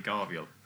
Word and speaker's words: Garviel: No, because it's come out as Cajabriel. – Oh, right. – Cajabriel, but it's actually Garviel. Garviel Loken.